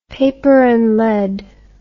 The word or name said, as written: paper and lead